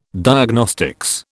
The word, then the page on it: Diagnostics: British English